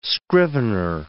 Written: scrivener

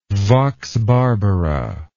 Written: vox barbara

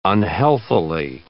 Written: unhealthily

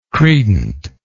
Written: credently

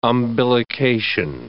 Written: umbilication

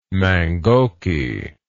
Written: mangoky

